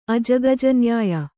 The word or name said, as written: ಅಜಗಜನ್ಯಾಯ